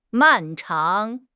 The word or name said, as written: 漫长